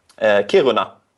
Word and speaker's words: Kiruna: So, the Ice Hotel, I think, is up in, I don't know how to pronounce it, Kiruna? – Kiruna. – Okay, there you go.